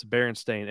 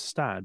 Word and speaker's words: Berenstain: I'm gonna say stain because obviously it's spelt that way. It's Berenstain, S-T-A-I-N. Everybody thinks it was Stein, Berenstain, S-T-E-I-N.